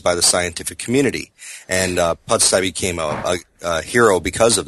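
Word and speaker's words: Pusztai: Is it Pustai? Did I say that correctly? – Pustai, yeah.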